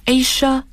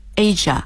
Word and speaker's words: Asia: 很多听众朋友会说，咦，我听到的发音是 Asia，那么两种发音 Asia、 Asia，现在 Asia 这个发音在美国更时髦一些。 – Asia，它的形容词形式就是 Asian。 – 那么我们说亚洲的亚洲人就是 Asian Asian people or people in Asia 都可以。